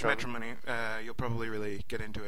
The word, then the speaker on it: Metronomy: Metronomy. Yeah. They say it in the live album, so I know I'm right.